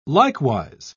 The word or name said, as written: likewise